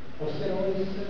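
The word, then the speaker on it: Oosterhuis: Vedete questi esseri, questo anche Osterois si pronuncia in questo modo, è un architetto olandese, uno di questi bravi architetti.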